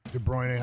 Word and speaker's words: De Bruyne: De Bruyne, how you say it? Redheaded guy. – Mm-hmm. That's right.